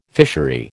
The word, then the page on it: fishery: American English